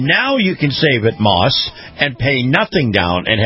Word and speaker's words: Maus: And this portion of the program brought to you by our good friends at Moss Family Automotive. Moss is spelled M-A-U-S,